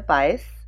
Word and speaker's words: Buyse: Hi, my name is Nadia Buyse, rhymes with Miami Vice.